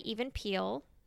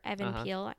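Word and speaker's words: Even Piell: I call him Even Peele. A lot of people say Evan. – I say Evan Peele. – Evan Peele. – We've learned I pronounce things weird. – Well, we pronounce things differently. I don't think either of us are right or wrong.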